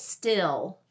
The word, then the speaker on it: still: So the word today that I'm wanting to focus on is the word still, S-T-I-L-L. And if you're from the South like me, it's not just one syllable, it's two syllables, still, still.